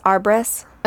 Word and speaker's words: Arbres: It's spelled A-R-B-R-E-S. Arbres. – Oh, okay. – Yeah. B-E-L, Arbres.